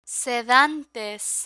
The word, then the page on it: sedantes: spanish